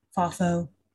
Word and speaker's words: faux: Episode two Friend or Fall. Faux fall. Faux? Because it's spelled F A U X.